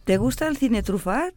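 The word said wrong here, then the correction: Truffaut: No, de trufó.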